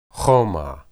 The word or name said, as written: humah